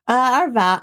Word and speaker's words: Arvada: Arvada.